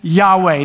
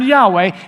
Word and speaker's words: Yahweh: and that name is Yahweh. Now, I don't even know if I'm saying it right, because in the Hebrew text, it's all consonants, Y-H-W-H. We don't know if there are any vowels to it.